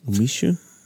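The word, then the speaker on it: Umeshu: Umishu? – Yeah. – Umishu.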